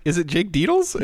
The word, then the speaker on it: Dettles: So he said it rhymes with the Bettles. Jake Dettles. Thanks, Jake, for that pronunciation. And then Zachariah Ruff. – Wait, is it Jake Deedles? – Yes. Yes. It is.